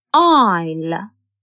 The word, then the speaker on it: aisle: S is a silent letter in aisle.